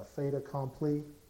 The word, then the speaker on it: fait accompli: It is, pronounce it, Barbara, a fait accompli.